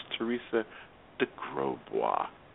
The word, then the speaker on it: Teresa de Grosbois: I used to fumble her name like mad, but it's Teresa de Grosbois. It also helps that my producer phonetically wrote it out for me.